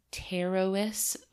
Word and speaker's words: Pterois: So, first of all, the red lionfish's scientific name is Pterois volitans. That's with a silent P, by the way.